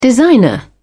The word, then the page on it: designer: British English